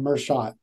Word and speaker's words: Merschat: You got it right the second time, Merschat.